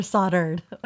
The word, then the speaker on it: solder: Or soldered. – Oh! There's a shirt for you. It's pronounced solder. Solder. – That's why there's an L.